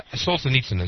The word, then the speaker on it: Solzhenitsyn: Solzhenitsyn is the correct pronunciation of the name.